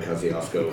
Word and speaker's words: Kosciuszko: So Mount Kosciuszko, I think it is. – You are correct. It is Mount Kosciuszko.